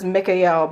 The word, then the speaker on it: Mikael: The pronunciation that I work with is Mikael, because El is an ancient Hebrew name for God.